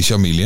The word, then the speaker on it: Chamilly: Marquise de Chamilly, zeg ik het goed? Chamilly of Chamilly? Chamilly, hè? – Chamilly.